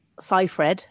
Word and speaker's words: Seyfried: Seyfried is how you say it, apparently.